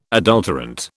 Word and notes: adulterant: British English

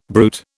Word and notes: bruit: British English

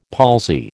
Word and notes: palsy: American English

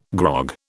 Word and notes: grog: British English

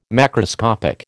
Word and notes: macroscopic: American English

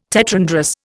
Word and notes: tetrandrous: Indian

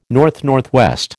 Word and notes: NNW: American English

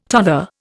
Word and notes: tother: Indian

